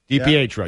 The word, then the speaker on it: DiPietro: I mean, no, but I'm saying we got a request because he said he ripped a former goalie, Rick DiPietro. I didn't pronounce that right. – DiPietro, yeah. You got it.